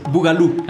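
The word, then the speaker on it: boogaloo: No dije bien Bogaló, dime cómo se dice. Bugalú. Ah, Bugalú. Tú que conoces más de lo del ritmo.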